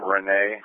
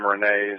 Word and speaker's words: Renee: First name, Renee. Gagliano is spelled George, Adam, George, Lincoln, Ida, Adam, Nora, Ocean. First name, Renee, is going to be Robert, Edward, Nora, Edward, Edward.